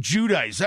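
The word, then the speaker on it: Giudice: Yeah, who can't figure out how to pronounce her own goddamn last name. Oh, I'm Judice. I'm Judice.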